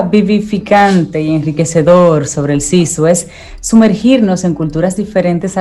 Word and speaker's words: sisu: Sisu o Seso, que es como más o menos se pronuncia,